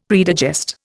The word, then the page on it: predigest: Indian